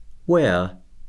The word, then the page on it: wear: verb